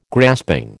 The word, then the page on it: grasping: American English